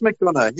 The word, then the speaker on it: McDonagh: I think it's McDonagh.